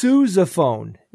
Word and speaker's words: sousaphone: All right, well, before I launch into it, I mispronounced a word, and many people called me out on it. I mean, I should have been there. – Who is surprised? Anybody? No. Not one hand went up. So I said the sousaphone, because, you know, it's spelled S-O-U-S-A, and that's basically, yeah. – It's sousaphone. – It's sousaphone. – Yes.